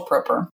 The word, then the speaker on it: Proper: It's actually with a hard O. It's proper, Rachel Proper.